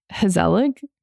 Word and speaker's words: gezellig: Tell me the word again. – Hazelig. Hazelig. Spelled with a G.